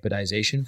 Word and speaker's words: apodization: It's actually an apodization filter.